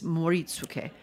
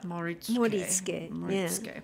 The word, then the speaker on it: Moritsuke: I always miss a syllable in Japanese words somehow. – They're pretty flat. Yeah. And some people try to find where the accent goes, and the answer is nowhere. It's flat. – Moritsuke.